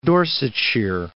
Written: Dorsetshire

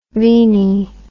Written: veni

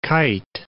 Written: kyte

